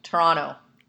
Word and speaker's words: Toronto: You say it like the second T doesn't exist. – Oh, so like Toronto. – Toronto.